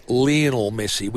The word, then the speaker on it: Lionel Messi: L-I-O-N-E-L, Messi, the great soccer player. Now, because he's internationally known as Lionel Messi, which is the way they pronounce it, Lionel Messi, I think, and I call him Lionel Messi now because that's how he's known internationally and that's how he pronounces his name.